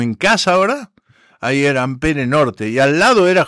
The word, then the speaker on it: Ampère: se llama Ampere, que es Ampère, en realidad se pronuncia en francés, Ampère.